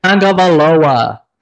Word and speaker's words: Tagovailoa: 28, Tua Tagovailoa. I hope I said that right. – Tagovailoa.